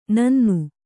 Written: ನನ್ನಿ

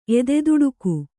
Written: ಎದೆದುಡುಕು

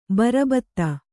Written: ಬರಬತ್ತ

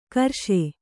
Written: ಕಾರೀಷ

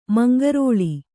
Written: ಮುಂಗೊರಲ್

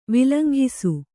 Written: ವಿಲಂಘಿಸು

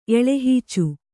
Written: ಎಳೆಹೀಚು

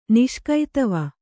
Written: ನಿಷ್ಕೈತವ

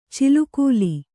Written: ಚಿಲುಕೂಲಿ

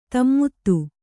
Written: ದಮಥ

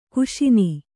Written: ಘೋಸನೆ

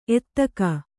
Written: ಎತ್ತಿಗ

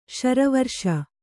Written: ಶಾರ್ವರೀಶ

